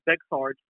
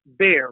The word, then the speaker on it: Bexar: And by the way, just so that the viewers are aware, so our county down here, while it phonetically sounds like Bexar, it's actually pronounced bear, like the animal.